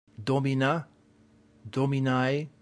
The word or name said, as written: domina